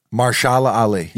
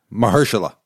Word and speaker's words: Mahershala Ali: Mahershala Ali. – Yeah. Mahershala.